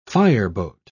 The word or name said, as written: fireboat